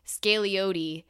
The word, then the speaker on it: Scagliotti: Um, and she says, The G is silent, the O is long. Scagliotti